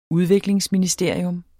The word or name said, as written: udviklingsministerium